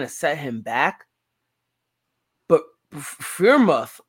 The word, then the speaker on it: Freiermuth: I mean, Freiermuth is how you pronounce Freiermuth, Freiermuth, Frank Footer, whatever.